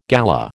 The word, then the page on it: galah: American English